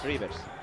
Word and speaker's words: Reuvers: La fuente te ha dicho que es Rivers.